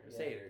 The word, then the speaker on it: Satyr: S-A-T-Y-R. Seder. It's a Seder.